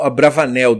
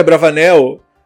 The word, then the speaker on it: Abravanel: Bom, nós falamos que íamos falar um pouquinho do Abravanel, Don Itzhak Abravanel.